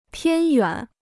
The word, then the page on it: 偏远: piān yuǎn